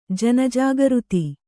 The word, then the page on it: ಜನಜಾಗೃತಿ: jana jāgřti